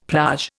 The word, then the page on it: plage: Indian